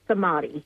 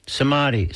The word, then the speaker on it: Somity: So It's Somity. – Somity. So it's the middle. – There you go. – It's the middle syllable. That's the emphasized one.